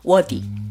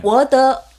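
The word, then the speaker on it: 워디 (我的): 근데 보통 노래를 부를 때 더를 뒤로 가볍게 발음을 해요. 그래서 네. 워디. – 워디. 노래에는 성조가 없잖아요. – 그러니까. – 그냥 이렇게 부르면 되죠. – 워더 워더칭 하면 좀 둔탁하잖아요.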